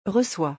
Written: recois

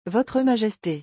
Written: votre majeste

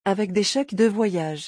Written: avec des cheques de voyage

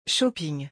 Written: shopping

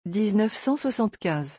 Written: 1975

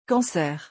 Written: cancer

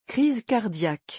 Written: crise cardiaque

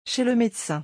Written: chez le medecin

